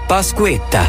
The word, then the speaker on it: Pasquetta: Pasquetta.